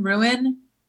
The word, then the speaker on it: ruin: The word R-U-I-N, ruin. My whole life, I just pronounced it one syllable, rune. – Rune, yeah. – And then people made fun of me enough that every time I go to say that, I say ruin.